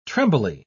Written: trembly

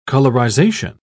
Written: colorization

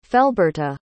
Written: felberta

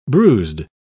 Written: bruised

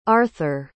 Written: arthur

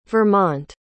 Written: vermont

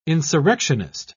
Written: insurrectionist

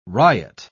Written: riot

